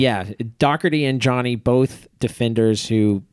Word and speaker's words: Doherty: Spelled Doherty. For some reason, it's pronounced Doherty. I never really understood that. I think it might be an Irish thing, but a defender who brings it up the field.